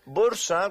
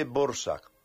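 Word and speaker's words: Dvořák: Borsat se escribe Dvorak, ¿vale? Con V y vale. Terminado con K. Pero en checo se dice Borsak.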